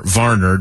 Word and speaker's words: Warner: are immediately going to be Dre Greenlaw and Fred Warner, or Varner, depending on your pronunciation.